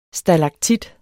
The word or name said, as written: stalaktit